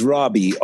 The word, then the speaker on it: Raabe: My last name is Raabe, R-A-A-B-E.